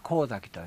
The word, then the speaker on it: 神前: ということで、神様の前と書いて神崎と言いますが、エンターキー。